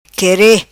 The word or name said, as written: quere